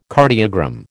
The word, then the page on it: cardiogram: American English